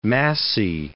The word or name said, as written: massy